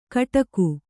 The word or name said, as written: ಕಿಡಿಕೆ